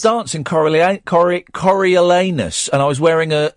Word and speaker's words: Coriolanus: Coriolanus. – Coriolanus.